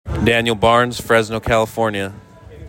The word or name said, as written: Daniel Barnes